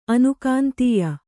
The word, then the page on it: ಅನುಕಾಂತೀಯ: anukāntīya